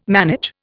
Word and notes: manage: Indian

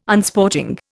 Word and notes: Unsporting: Indian

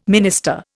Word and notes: minister: Indian

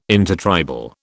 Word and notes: intertribal: British English

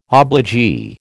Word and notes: obligee: American English

